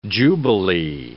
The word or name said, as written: jubilee